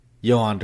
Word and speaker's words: Jhoan: Is it Jhoan? – Jhoan Duran, yeah.